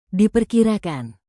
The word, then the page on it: diperkirakan: indonesian